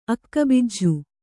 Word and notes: ಆಖುಭುಜ: ākhubhuja

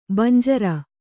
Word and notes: ಬಂಜರು: Kannada

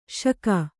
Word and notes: ಶಕ: Kannada

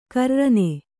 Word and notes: ಕಿರ್ರನೆ: kirrane

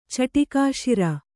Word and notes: ಚಟಿಕಾಶಿರ: Kannada

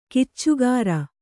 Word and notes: ಗೀಚುಗೆರೆ: gīcugere

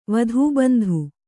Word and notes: ವಧೂಬಂಧು: Kannada

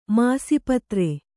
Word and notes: ಮಸಿಪಾತ್ರೆ: masi pātre

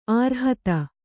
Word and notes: ಅರ್ಹತೆ: Kannada

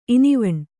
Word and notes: ಇನಿವಣ್: Kannada

